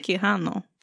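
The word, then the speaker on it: Quijano: Kehano.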